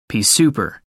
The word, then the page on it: pea-souper: American English